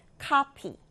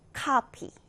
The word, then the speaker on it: copy: 코피도 아니고 커피도 아니고 카피. 카피 이렇게 아 발음으로 해줍니다.